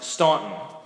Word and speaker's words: Staunton: To which I said, "I think it's actually pronounced Staunton."